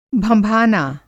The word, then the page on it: भगिनीपति: Hindi